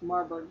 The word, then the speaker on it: Marburg: Acute, often fatal form of hemorrhagic fever caused by filovirus, Marburg virus, that normally lives in African monkeys.